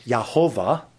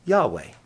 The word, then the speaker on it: Yahweh: As scholars studied this and delved into the Hebrew language, they came to the conclusion that it probably wasn't pronounced Yahovah. That was something that was created, but it was probably pronounced Yahweh. Yahweh. Y-A-H-W-E-H.